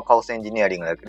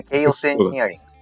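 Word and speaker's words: Chaos Engineering: 日本語で言うと、まあ、カオスエンジニアリングだけど、一応なんか発音的にはケイオスエンジニアリングっていう発音になります。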